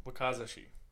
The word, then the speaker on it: Wakizashi: Uh, hope I pronounce this right. A uh, a, a wakazashi Wakizashi. Wakizashi. It's a smaller.